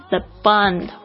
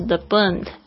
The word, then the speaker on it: The Bund: 接下来是上海著名的外滩，是 The Bund 或者是 The Bund 都可以。